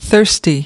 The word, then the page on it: thirsty: /ˈθɜːrsti/